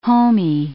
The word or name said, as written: homie